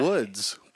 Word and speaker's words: Woods: I've told you 17 times it's the Morning Woods with an S. – Oh. – It's plural. It's why? – The Woods.